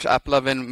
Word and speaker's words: AppLovin: AppLoving, that's how you say it, not loving. So there's no N there, there's no ing.